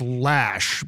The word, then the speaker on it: Loesch: But I've been pronouncing Dana's name Loesch all these years. Is it supposed to be pronounced Lash? – You know what? I had that same question on Stephanie Miller's show this morning. I wasn't sure. So I go with Lash because I've heard it said Lash.